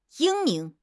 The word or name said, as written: 英明